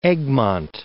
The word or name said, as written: Egmont, Mount